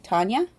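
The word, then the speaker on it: Tanja: One of the women who escaped him, I'm going to say her name is pronounced Tanya. It's T-A-N-J-A, Tanya.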